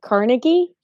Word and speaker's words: Carnegie: You guys say Carnegie different than the main name. So wait. We say liquor store. – You pronounce it Carnegie? – Yes, that's how it's pronounced. – It's Carnegie.